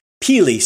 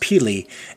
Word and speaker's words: pili: Peely, spelled P-I-L-I, but pronounced peely, is an oblong nut from Southeast Asia and the Pacific Islands.